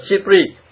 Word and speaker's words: SIPRI: Theo nhận định của Viện Nghiên cứu Hòa bình Quốc tế, viết tắt là SIPRI mà đọc là SIPRI,